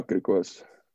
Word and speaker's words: Griquas: Yeah, Griquas.